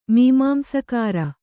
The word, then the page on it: ಮೀಮಾಂಸಕಾರ: kannada